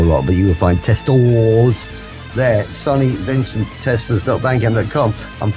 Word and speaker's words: Testors: Right, this is a band, 1976-77, Prime Primitive, CBGBs, Max's Kansas City's regulars, Testors, but it's T-E-S-T-O-R-S, so Testors. I'm sure you would pronounce it Testors, but I don't know whether that's how the Americans spell Tester or not.